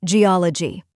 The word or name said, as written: geology